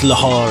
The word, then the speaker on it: Lahore: So Bollywood, that's a concatenation of spoonerism, or whatever, however you say it, of Hollywood and Bombay, and then Lollywood is Lahore, I think it's pronounced, and so Lollywood, Pakistan.